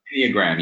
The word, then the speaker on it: Enneagram: Is it pronounced Enneagram? – Enneagram, yeah.